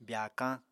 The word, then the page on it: byaakañ: Apatani